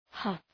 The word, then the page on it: huff: hʌf